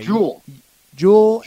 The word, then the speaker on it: Juhl: It's capital J-U-H-L on the pronunciation guide. – Okay. – Jewel Erickson Ek.